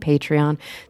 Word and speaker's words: Patreon: And anybody else who is listening who wants to make a little pledge there on Patreon, that's how you pronounce it, wants to be a patron on Patreon, there's some great goodies there for you.